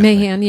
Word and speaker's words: Mahan: To your point, the tech bros all got behind Matt, is it Mahan? How do you pronounce that? – Mahan, yeah.